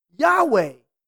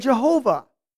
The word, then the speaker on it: Yahweh: this Hebrew word, YHWH, is more accurately pronounced Yahweh rather than Jehovah.